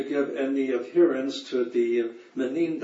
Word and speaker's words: Minim: És ez a, how do you say, Menim? – Menim, M-I-N-I-M, Menim.